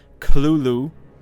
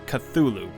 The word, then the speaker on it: Cthulhu: First, a note on pronunciation. Lovecraft himself gave several differing pronunciations of the creature's name throughout his life, including Cthulhu, with a heavy guttural first syllable. The most common pronunciation, however, is Cthulhu, popularized much later after Lovecraft's death.